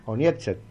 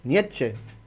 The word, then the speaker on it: Nietzsche: Nietzsche. ¿O cómo? Nietzsche. Bueno, ahí está. Listo. Bueno, yo fui a la misma escuela de Gallardo. Okay. Seguimos. – Nietzsche. – Ahí está. El Che se lee. Nietzsche.